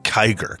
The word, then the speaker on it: Kiger: How about Kiger? Because it's spelled K-I-G-E-R, like tiger.